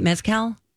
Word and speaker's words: mezcal: And he had some very special guests behind the bar serving up drinks to promote their new mezcal. Is that how you say it? Mezcal? – Yep. – Mezcal. – Yep. – It's a weird word.